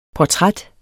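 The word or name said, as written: PORTRÆTS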